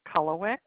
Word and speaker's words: Kulhawik: Kathleen Kulawick. Last name is spelled K-U-L-H-A-W-I-K.